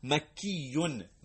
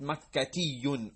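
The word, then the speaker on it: مكيّ: It's almost not really pronounceable. Makiyun, makiyun, not makatiyun or something of the sort. Makiyun, madrasiyun. We drop the ta.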